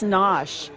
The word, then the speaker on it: Gnoss: That's Nosh. – Yeah. – It's spelled G-N-O-S-S.